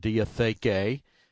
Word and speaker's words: diatheke: So when we look at the word covenant in the New Testament, it's a Greek word, diatheke.